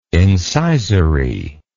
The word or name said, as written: incisory